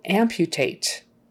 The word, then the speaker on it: amputate: Okay, we've got that U in the middle of the word and it's surrounded by consonants. So there's kind of a Y sound in front of it. Amputate. Not amputate. Amputate. Amputate. Amputate. And that's a verb. And the stress is still on the first syllable.